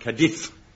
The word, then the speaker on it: Cadiz: It's pronounced Cadiz. – Oh, all right then, Cadiz.